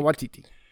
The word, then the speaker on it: Waititi: Waititi.